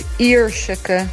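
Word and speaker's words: Yerseke: Het is Ierseke. – Ierseke. – En ik denk dat de Zeeuwen het nog beter kunnen uitspreken. – Ja. Ja, want dat zit natuurlijk in Zeeland, of het ligt. Ierseke, sorry hoor. Ik weet niet wat ik zei. Zei ik Jerske of zo?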